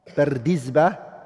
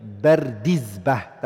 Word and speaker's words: Bardizbah: Bardizbah, it's a Persian word, and it means farmer. That's the correct way of saying the name. Bardizbah, that's how you say it.